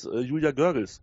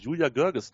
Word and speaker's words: Görges: Also ich glaube nicht, dass ein Stadionsprecher in Indian Wells Julia Görges, siehst du, jetzt sage ich es auch schon falsch, Julia Görges richtig aussprechen kann.